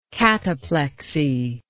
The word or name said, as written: cataplectic